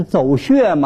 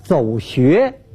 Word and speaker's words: 走穴: 演艺圈里头啊有一种专业用语，像什么走穴呀，哎，那个说不念走穴吗？这读音读错了，啊，应当是走穴。